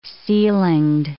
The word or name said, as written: ceilinged